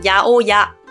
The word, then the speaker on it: 八百屋: 我在这边可以跟大家分享一个例子：八百屋，日文读作牙欧牙，八百屋，屋是屋子的屋，这是一家店，大家可以猜到它是什么店吗？